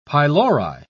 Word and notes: pylori: British English

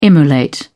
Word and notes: immolate: British English

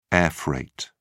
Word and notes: airfreight: British English

